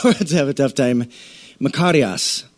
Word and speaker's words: Makarios: The Greek word for blessed is a word called Makarios. And you have to roll your R.